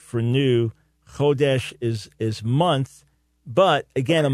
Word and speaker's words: Chodesh: Yeah, the first thing, the first word, Chodesh, so you did a good job on it, but accent on the first syllable. Chodesh, in itself, it means month.